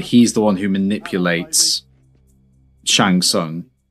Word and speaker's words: Shang Tsung: He's Shang Tsung.